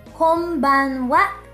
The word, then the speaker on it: こんばんは: You may want to take note that when the N, that's the N, character is followed by B, P, and M sounds, it's more naturally pronounced as M, that's an M. That's why, although it's spelled with the N, the N character, it is pronounced こんばんは naturally.